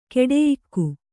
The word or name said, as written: ಖೋಡಿಯೋಗು